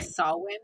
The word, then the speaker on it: Samhain: So on the night of October 31st, they celebrated with a festival called Samhain. Ooh, Samhain. I like that name. Ooh, sorry, I'm moving stuff. – It sounds like you did say sorry ahead of time, but Samhain.